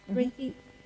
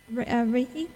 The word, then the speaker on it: Reiki: Reiki. Did I say it right? – Yeah. – Reiki healing.